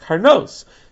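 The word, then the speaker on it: Karnos (קרנות): Now, in all three of those places, it's spelled without the Vav. But in all three places, it's pronounced Karnos.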